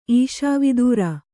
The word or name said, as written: ಈಷಾವಿದೂರ